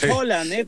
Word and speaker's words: Haaland: Estamos muy mal en la pronunciación de Erling Haaland, no es Haaland, nosotros lo decimos, lo mexicanizamos, pero es Haaland, cuando se ponen en noruego dos as seguidas, eso se menciona como, entonces, estás aprendiendo algo nuevo aquí, niño Pons, Entonces, son dos As, es una O, entonces es Haaland.